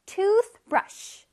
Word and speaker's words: toothbrush: 자, 근데 얘들아, 여기 중간에 TH 쇼로로롱 요거 보이죠? 그 TH가 나올 때 우리 어떻게 발음하죠? 뜨, 뜨, 뜨. 실뜨기 하듯이 혓바닥으로 뜨 이렇게 튕겨줘야 된다 그랬죠? 다시 one more time. Toothbrush.